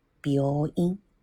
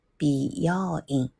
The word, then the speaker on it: 病院: 这个医院呢，在日语里叫“ 病院”，这个发音一定要注意哦，它是拗音，所以叫“ 病院”。你要发不好，如果发成“ 比要音”，那就是另外一个单词了。那个单词叫“ 美容院”。病院、医院、美容院、美容院，这次两个单词哦，心里想想挺有意思的。